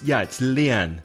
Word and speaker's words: Leanne: We have a voice message from, is it Leanne or Lianne? I'm not sure how to pronounce it. – It's Leanne, who wants to speak like a native English speaker, and she sent us a voice message.